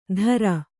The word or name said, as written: ಧರ